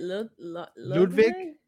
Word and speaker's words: Ludwig: Isn't it Ludwig? Well, the V in German, the W is pronounced like a V. So Walter was supposed to be Walter.